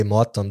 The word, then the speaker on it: Muqattam: They say Muqattam, they don't pronounce the Qaf in the Arabic or the Egyptian dialect.